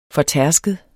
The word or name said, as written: fortærsket